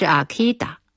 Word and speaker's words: Akita: 秋田的英语发音是 Akita，所以叫了阿 K。